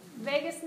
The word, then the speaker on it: vagus: Vegas nerve. I always make the same dumb, corny jokes, but it is not Las Vegas. And you're not leaving it, right? It's spelled V-A-G-U-S.